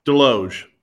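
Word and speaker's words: Desloge: Deloge. Deloge. – Deloge. Okay. Okay. There you go. I was pronouncing the S, but that so that sounds good.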